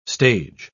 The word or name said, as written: stage